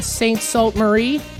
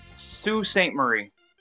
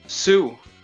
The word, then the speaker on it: Sault Ste. Marie: And soon to be St. Salt Marie. I believe that's in Ontario. – Yeah, but you got to pronounce it Sault Ste. Marie. – Wait, is that how it's pronounced? – Sue.